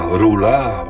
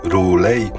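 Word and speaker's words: R'lyeh: To projekt Samuela Dacko, wydany nakładem Mind Trick Records, epka Memories, a Rula lub Roulet, bo różnie wymawia się to miasto z opowiadań Lovecrafta.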